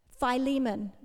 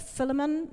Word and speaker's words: Philemon: See, Onesimus is also mentioned in a different book of the Bible, the book of Philemon. That's a hard one because I've said it Philemon my whole life. And then I looked up the pronunciation and it's actually Philemon.